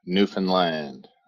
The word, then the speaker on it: Newfoundland: So it's understand Newfoundland. You get the alliteration and the flow. So work with that anytime you have trouble.